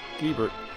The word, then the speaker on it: Gebert: Gordon Gebert. I read that that's how you're supposed to pronounce his name. I hope I got that right. Gebert.